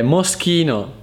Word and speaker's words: Moschino: Ultimo, un altro marchio della moda molto famoso che sono difficile la SCH, Moschino, quindi Schino, Schino, Moschino, SCHI, Schi, Schi, Moschino, Moschino, Moschino, un brand molto riconosciuto mondialmente, Moschino, Moschino.